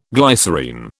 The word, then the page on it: glycerine: British English